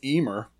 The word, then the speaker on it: Emer: Tokmark Emir, or Emery, The Wooing of Emer, lists Samhain as the first of these four quarter days, quote-unquote. – Emer. – E-M-E-R? – Yeah.